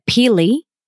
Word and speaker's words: pili: Pili? – Pili, and it's spelled P-I-L-I-N-U-T-S.